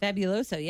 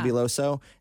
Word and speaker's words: Fabuloso: Fabuloso, yeah. – Fabuloso.